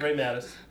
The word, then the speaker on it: Ray Matis: Ray Mattis.